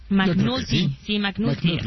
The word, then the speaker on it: McNulty: Oh, si se pronuncia así. McNulty. Ahí está bien.